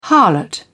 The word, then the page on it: harlot: British English